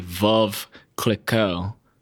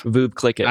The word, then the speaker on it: Veuve Clicquot: I kept pronouncing, it's supposed to be Veuve Clicquot, and I kept saying Veuve. – Veuve Clicquot. – I kept calling it Veuve Clicquot, and I thought it sounded good.